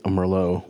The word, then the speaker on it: Merlot: It bothers me that you don't pronounce the T. I mean, it's just the Merlot. – It's a Merlot.